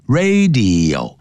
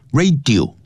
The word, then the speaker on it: radio: 第二念 serious 就像收音机叫 radio 不要念成 radio radio serious serious radio 啊，这样念 radio 像影像呢视频叫 video video 不要 video 啊 video radio serious Serious problems to humans, 对人类啦 plants, 植物啦 animals, 动物 and the entire environment as a whole, 以及包括整个环境。